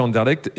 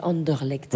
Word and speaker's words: Anderlecht: Mais j'avais essayé une sauce, une fois ils avaient sorti une sauce violette pour Anderlecht, en hommage à Anderlecht, et franchement, c'était pas terrible. – Ouais, excusez-moi, mais on dit Anderlecht.